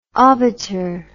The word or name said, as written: obiter